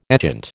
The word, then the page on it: etchant: American English